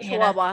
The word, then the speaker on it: Chihuahua: Chihuahua.